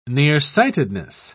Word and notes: nearsightedness: British English

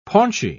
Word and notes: paunchy: British English

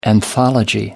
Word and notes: anthology: American English

